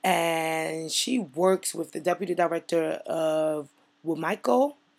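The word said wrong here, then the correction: Wicomico: Correct me if I say it wrong, Lori. – You say it wrong. It's WCOMICO.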